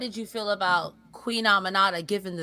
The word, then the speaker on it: Aminata: So yeah, I think, Queen, how do you pronounce it? – Aminata. That's how they were pronouncing it.